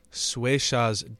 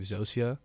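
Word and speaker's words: Xocia: It's X-O-C-I-A apostrophe S, dance. And it also has, they anticipated people not really being able to pronounce that, maybe saying Zosia. And so they put underneath a pronunciation guide and it says Swayshaw.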